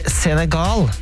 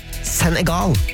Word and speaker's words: Senegal: Han skriver, det heter Senegal med lang E. Alt annet høres ut som man prøver alt for hardt å snakke fransk på en tirsdag ettermiddag. Ida skriver at man skal uttale det Senegal med, med trykk på slutten.